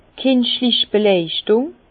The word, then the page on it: d' kinschlich Beleuchtùng: Bas-Rhin; Herrlisheim